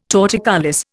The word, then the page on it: torticollis: Indian